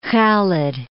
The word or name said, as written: Khalid